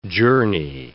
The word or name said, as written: journey